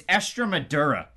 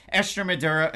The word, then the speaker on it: Estremadura: Estremadura is what his name is. I mispronounced it in all of that video, but Estremadura, Achilles Estremadura, the Canadian, he wasn't able to get it going.